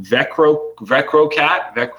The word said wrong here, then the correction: Vectorcat: we're sorry, putting you through some real hoops here. – There's a T in there. Vectorcat, I think. – Vectorcat.